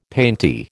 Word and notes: painty: American English

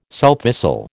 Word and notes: sowthistle: American English

